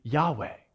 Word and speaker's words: Yahweh: That is the Tetragrammaton, and we have just put Lord in there, but probably the best way to pronounce that would be Yahweh. That's the best understanding that scholars have for how to say it.